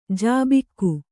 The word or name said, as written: ಝಾಬುಕ